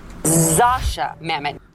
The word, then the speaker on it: Zosia Mamet: Zosia Mamet.